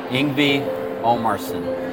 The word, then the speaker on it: Omarsson: So here we are, Ingvi Omarsson.